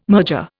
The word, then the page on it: merger: Indian